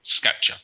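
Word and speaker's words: scaccia: And can you spell it again? – S-C-A. – Yep. – C-C-I-A. Skatchia.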